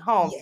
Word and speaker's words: Holmes: Candace Holmes.